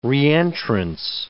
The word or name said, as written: reentrance